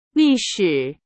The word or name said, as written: 历史